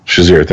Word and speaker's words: Shazier: Shazier, thank you.